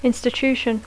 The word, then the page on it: institution: English